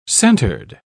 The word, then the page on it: centered: British English